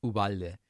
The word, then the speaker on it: Uvalde: And the problem with me not just pronouncing it the way it is, which is Uvalde, is I don't actually know which wrong pronunciation should I use.